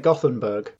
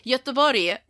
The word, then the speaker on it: Göteborg: I want to say it Gothenburg. – Yes! – You're from Gothenburg. – I am in Gothenburg. – But should it be Gothenburg or something? – Göteborg.